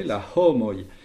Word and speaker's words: homoj: Et pour le H aspiré, la homoi.